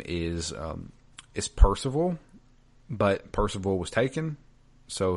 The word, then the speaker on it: Parzival: They pronounce it essentially as close to Percival as you can, just with the Z. So it's Percival.